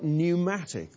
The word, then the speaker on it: pneumatic: In the Greek language, it's pneumos, from which we get, spelled with a P, believe it or not, from which we get words like pneumatic.